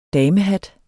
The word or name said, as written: damehat